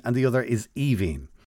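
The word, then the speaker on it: Aoibheann: The second one is pronounced Evine. – Mm. – And I think it's because the E-A-N-N somehow affects the first five letters.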